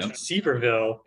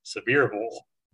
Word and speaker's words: Sevierville: And it's not Sevierville. It's Sevierville.